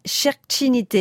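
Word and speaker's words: Šerkšnytė: La compositrice, c'est Raminta Cherchinite. Vous savez que je suis allée très loin pour avoir la bonne prononciation. – Vous avez été jusqu'en Lituanie.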